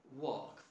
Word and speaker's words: walk: Walk, for example, right? – Walk. – Yes. The L is silent.